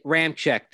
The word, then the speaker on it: Ramczyk: Ramchek.